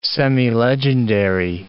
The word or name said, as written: semilegendary